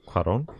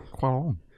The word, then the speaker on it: Cuarón: How do you pronounce his name? – Cuarón. – Cuarón. Yeah.